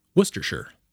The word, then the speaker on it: Worcestershire: By the way, that's how you pronounce it. You say the name of the second biggest city in Massachusetts, Worcester, and then you pretend somebody just asked you if you'd like a stick of gum, and you just say, sure, Worcestershire. Easy.